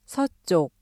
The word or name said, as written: 서쪽